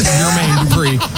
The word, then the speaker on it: Jermaine: I believe it's actually a soft J, and it's pronounced Jermaine Dupri. – Yeah, you are correct.